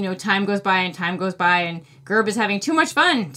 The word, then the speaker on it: Gurb: So again, that one is called No Word from Gerb. It's spelled G-U-R-B.